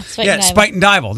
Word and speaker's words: Spuyten Duyvil: I think, I don't know why or how, I know how to correctly pronounce this, but the Metro Parks is buying the Spuyten Duyvil golf course. – Really? – Did I say that right? – Mm-hmm. Yeah, Spuyten Duyvil.